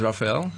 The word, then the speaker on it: Raphael: Actually, it's also the reason because my name is Raphael, and a lot of people have difficulties to pronounce it, and they call me Rachel.